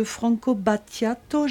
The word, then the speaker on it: Franco Battiato: Alfonsina, cette musique, donc c'est de Franco Battiato, j'ai bien prononcé.